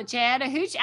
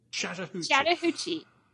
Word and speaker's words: Chattahoochee: Way down yonder on the Chattahoochee. Alan Jackson song. Don't you know that song? Chattahoochee. – I do, but I thought I'd say it properly. Okay. Chattahoochee.